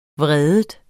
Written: vred

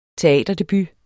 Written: teaterdebut

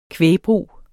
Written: kvægbrug

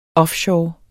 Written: offshore

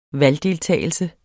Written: valgdeltagelse

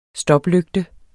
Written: stoplygte

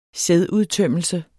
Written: sædudtømmelse

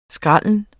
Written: skralde

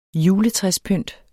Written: juletræspynt

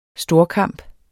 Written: storkamp

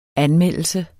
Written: anmeldelse

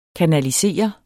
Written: kanalisere

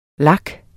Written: lak

